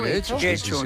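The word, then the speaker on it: Getxo: Guecho. Yo creo que lo pronunció bien.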